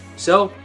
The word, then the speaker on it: éS: So, the big reveal. As I used to call it, ES, but I have learned it's actually pronounced S.